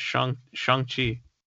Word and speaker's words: Shang-Chi: Yeah, I thought it was Shang too, but apparently the proper pronunciation is Shang-Chi.